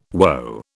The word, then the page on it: woe: British English